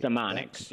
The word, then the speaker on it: StemoniX: I think it's called I can't pronounce it. Stemony, Stemony. – StemoniX, yes.